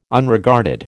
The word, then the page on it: Unregarded: American English